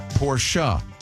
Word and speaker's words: Porsche: It is, because they say in German, the E is not silent. – Mm. – And it's named after Ferdinand Porsche, who's Porsche. – Correct. – That's how you would say his name.